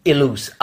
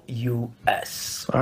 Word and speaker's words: Ilous: So because I was boxing at the time, like, so I stole his last name, but no, my real name is Ilous. Ilous.